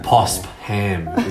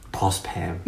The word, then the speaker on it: Phospham: Phospham. – It's not ham. That's not. It's fam. Mm. It's said like an F for sure.